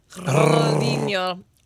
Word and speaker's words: Ronaldinho: Ronaldinho. – I don't know. – It was a video. Did I pronounce that correctly? – Ronaldo? – Should roll your R's a little more. – Ronaldinho.